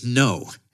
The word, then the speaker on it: Ngo: because it's spelled N-G-O. And I went to great lengths to make sure I was pronouncing it correctly. And then he just pronounced it No. And I had been told by every source I read that is not how to say it.